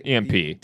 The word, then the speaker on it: EMP: EMP. – Did I say that wrong? – You said yeah. – D, but yeah. – EMP. – It's P? Okay. EMP.